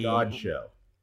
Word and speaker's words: Godchaux: They Godchaux. – Godchaux. I, I knew I was going to butcher that pronunciation.